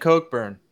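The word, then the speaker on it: Cockburn: Well, they pronounce it Cokeburn.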